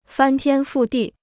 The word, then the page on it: 翻天覆地: fān tiān fù dì